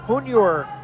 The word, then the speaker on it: Yunior: New pitcher on the mound for the Aviators is right-hander Junior Tour. First name is spelled Y-U-N-I-O-R. Last name is T-U-R. He wears number 99, 26-year-old from Cuba.